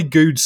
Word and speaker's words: Goode: And Charlie Goode's surname is actually pronounced Good, Neil.